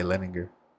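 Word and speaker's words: Leininger: Leninger? How do you say that? – I would say Leninger. Or okay. Leninger? – Yeah.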